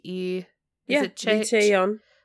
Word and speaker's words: Lee Chae-young: Do you know this person's, how to pronounce this person's name? It's like Lee Chae-young. – Okay, that's what I thought.